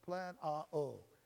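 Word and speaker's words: plan a-o (πλανάω): That word seduce comes from the Greek word plan o-u, or plan u-a, or plan a-o, plan a-o. Yes, plan a-o. It's spelled P-L-A-N-A-O, plan a-o.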